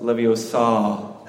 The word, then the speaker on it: Leviosa: I just want to remind everyone that it's pronounced Leviosa.